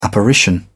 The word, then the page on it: apparition: British English